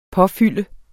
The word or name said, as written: påfylde